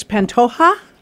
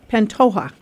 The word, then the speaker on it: Pantoja: If it's J-A, how do you pronounce that? This is Puerto Rican, so it's so it's Pantoja, Pantoja, J A.